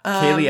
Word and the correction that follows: Kelley: Kelly is spelled K-E-L-L-E-Y. Very easy. Kelly.